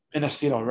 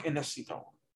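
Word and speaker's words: Innacito: Inesito, ready? I-N-N, that's Nancy Nancy. A-C as in cat. I-T as in Tom. O. Mark Inesito. Just like it's pronounced. Inesito.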